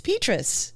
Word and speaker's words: Petras: This is why you come to us, because we can tell you these very important things, like how to pronounce Spencer Petras's name. – Yeah, Petras. – Petras. Yeah. Petras. Petras. Oh, Petras. We're going to get this right. – Dish. – Petri dish. Yes.